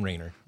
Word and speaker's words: Rainer: How do you say his last name? Rainer? – Tom Rainer. – Rainer, yeah.